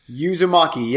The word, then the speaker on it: Uzumaki: Number 10 for me is Uzumaki? Is that how you say it? – Uzumaki, yep.